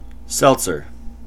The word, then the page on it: seltzer: American English